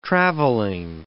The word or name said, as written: travelling